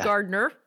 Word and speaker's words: Gardiner: is Gardiner. – Right? Yeah, Gardiner, not spelled like Gardner, but sounds like Gardner.